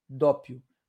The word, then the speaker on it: doppio: Então se escreve, ó, se escreve D-O-P-P-I-O, dópio, tá?